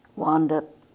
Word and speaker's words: Wanda: Wanda Pearson. W-A-N-D-A, initial B, capital P-E-A-R-S-O-N.